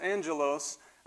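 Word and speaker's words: angelos (ἄγγελος): It's pronounced angelos, and it means a messenger from God, an angel, or one who was sent.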